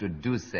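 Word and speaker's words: Duse: Well, of course, it should be pronounced Deuce, yes.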